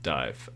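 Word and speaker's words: Dàimh: And I mean, and so Angus is in a big Celtic kind of folk group called Dive, which is actually it's a Gaelic word, just pronounced Dive.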